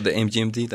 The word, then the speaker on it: MGMT: MGMT, seguro recordarán este proyecto que se pronuncia The Management, según yo, ¿no? – Sí, o The MGMT también.